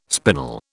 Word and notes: spinal: British English

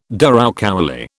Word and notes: douroucouli: British English

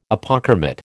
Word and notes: Apochromat: American English

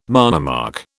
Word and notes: monomark: British English

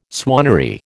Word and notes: swannery: American English

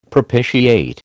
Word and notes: propitiate: American English

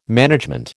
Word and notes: management: American English

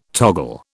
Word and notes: toggle: British English

